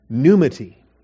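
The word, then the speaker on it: pneumati (πνεύματι): That is, the word is pneuma, but in the dative it's pneumati. And or pneumati, all right? We stress the first syllable. Pneumati.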